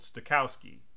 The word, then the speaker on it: Stokowski: He ended up going to lunch, and at lunch was Leopold Stokowski.